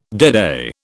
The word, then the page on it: deadeye: British English